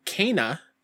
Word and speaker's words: Kena: Yep, I got to play an hour of, and I learned because of the gameplay demo, it's pronounced Kena, Bridge of Spirits.